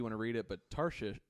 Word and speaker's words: Tarshish: Tarshish. – Shish. Yeah, we'll just... – Shish. That's how you say it.